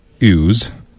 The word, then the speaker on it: ewes: It's easier for lambs to nurse sheared ewes. Am I saying that right? – Ewes. Yeah. That's a female sheep.